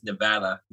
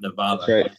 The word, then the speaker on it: Nevada: So anyone listening, it is Nevada, not Nevada.